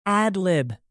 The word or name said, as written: ad-lib